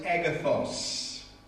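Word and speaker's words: agathos (ἀγαθός): And most often the word that is used is the Greek word which we would pronounce using English letters, agathos.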